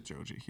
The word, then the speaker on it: Joji: It's called Slow Dancing in the Dark by Joji. Is that how you pronounce it? – Joji, yes.